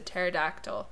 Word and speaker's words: pterodactyl: And he said a pterodactyl, because no one can hear them go to the bathroom because the P is silent.